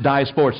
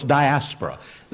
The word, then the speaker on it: diaspora: Some pronounce it diaspora, diaspora.